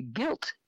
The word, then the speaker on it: gilt: is called a gilt, and it's spelled G-I-L-T, but the G is pronounced hard.